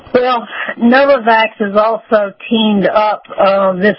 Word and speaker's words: Novavax: Okay, now, we have a company, Novavax.